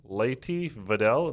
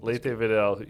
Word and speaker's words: Leyte Vidal: Today we're here with Frank Leyte Vidal. Did I say that right? – Yep, Leyte Vidal. – Leyte Vidal.